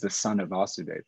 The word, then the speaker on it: Vasudev: And Vasudev with a short A.